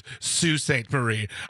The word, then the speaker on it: Sault Ste. Marie: It's pronounced Sault Ste. Marie.